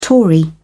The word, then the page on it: tory: British English